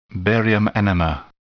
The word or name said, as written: barium enema